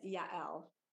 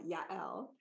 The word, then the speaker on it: Yael: My name is Yael. It is hyphenated on the second syllable, Yael. – So good. – I used to say that it was like Ya as in Yahoo and L as in Ellen.